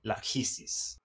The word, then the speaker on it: Lachesis: And in the Greek mythology, it's Clotho, Atropo, and Lachesis. – Excellent Greek pronunciation there.